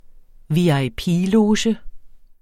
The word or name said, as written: VIP-loge